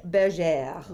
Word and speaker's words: Bergère: It's literally, it's spelled so, like burger, but I'm making it Bergère. – Really nice. Bergère.